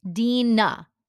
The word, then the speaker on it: Deana: And my name is Dina. Think, how do you spell Dean of College? Add an A. Or I'll say I'm a female dean.